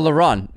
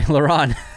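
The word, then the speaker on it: Laurent: Our nominees for the category are Lauren Technical for Anatomy of a Fall. I butchered that oh, LaRon. Sorry, LaRon. it's got the T at the end.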